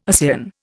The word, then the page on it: ASEAN: Indian